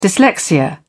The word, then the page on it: dyslexia: British English